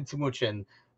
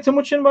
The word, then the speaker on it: Timucin: Hey, as a name like I have in Timucin, trust me, I am used to, I'm sure he's used to people butchering it regularly.